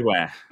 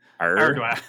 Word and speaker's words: Ardoin: Ardoin. So, R, like a pirate. – Ardoin. – Ardoin.